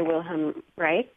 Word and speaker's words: Wilhelm Reich: The Function of the Orgasm by Wilhelm Reich. – Yes. – I don't know if I pronounced his name right. Yeah. – And I think you did.